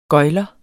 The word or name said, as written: gøgler